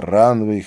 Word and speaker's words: Ранвейг: Это Ранвей, хотя пишется Ранвейг».